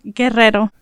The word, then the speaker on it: Guerrero: How would you say it? – Guerrero. – Guerrero. You got to roll that R, okay.